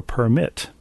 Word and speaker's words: permit: Is it permit like Kermit or permit? It's permit like Kermit, right? – Right, yes.